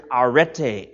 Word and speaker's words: arete: This word virtue, the Greek word arete, A-R-E-T-E, arete, the last E is a long E,